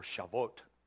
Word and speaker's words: Shavuot: When the day of Pentecost, or Shavuot, if you want to know how to spell that, it's, if you want to circle Pentecost and write the original Hebrew, it's S-H-A-V-U-O-T.